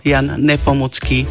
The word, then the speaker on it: Jan Nepomucký: Povedala som, že je český svetec a mučeník, preto Jan Nepomucký vyslovujeme tvrdo. Možno si to overiť, aj keď si vypočujete nejakú reláciu česku, alebo budete sledovať. Minule som pozerala nejaký kvíz a presne sa pýtali na Jana Nepomuckého. Takže tvrdo.